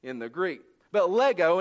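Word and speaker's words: lego (λέγω): Saying comes from the Greek word, and kids, you'll like this, lego, and that's exactly how you say it, lego.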